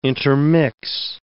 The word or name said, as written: intermix